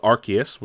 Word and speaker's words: Arceus: And it focused on Pokemon Legends Arceus, which I recently learned is the correct pronunciation, as well as a new trailer for Brilliant Diamond and Shining Pearl, the remakes of Diamond and Pearl.